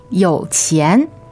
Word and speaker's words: 有钱: 有钱. 有钱. – All right, third tone, second tone.